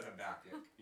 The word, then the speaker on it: Habakkuk: Habakkuk. – I heard one. Yes. I heard three different pronunciations, but I'm pretty sure everyone said Habakkuk.